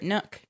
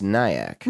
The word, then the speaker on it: Nyack: It's like that little secret nook. – Huh. – Is it pronounced nook or is it pronounced Nyack? – Nook. It's pronounced Nyack.